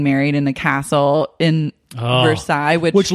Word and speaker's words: Versailles: Someone else told me that it's pronounced Versailles. That's how they pronounce it there.